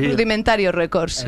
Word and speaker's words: Rudimentario Records: ¿Y el sello lo he dicho bien? – Sí. – Rudimentario Records. – Exactamente.